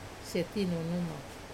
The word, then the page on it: setinunuma: Wamesa